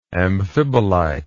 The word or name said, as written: amphibolitic